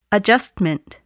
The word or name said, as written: adjustment